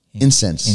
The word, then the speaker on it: incienso: Um, por ejemplo, como dice la palabra de Dios, la palabra dice en Salmos 141, versículo 2, que mi oración será como incencio. incienso, Incienso, sí. Incienso, ¿se dice así? Sí, incenso.